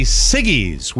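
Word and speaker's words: Siggi's: The fancy yogurt company Siggy's will pay one person. Did I pronounce that right? – Yes.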